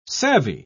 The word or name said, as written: savvy